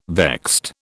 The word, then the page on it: vexed: British English